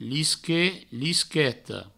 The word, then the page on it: lisquet, lisqueta: provençal; lisquet, lisqueto